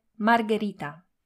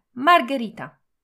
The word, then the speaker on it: margherita: Podobna zasada dotyczy wymowy wspomnianej wcześniej pizzy margherita. W nazwie margherita literka H znajduje się pomiędzy literkami G a E i wymusza na nas twardą wymowę. Margherita, a nie margherita.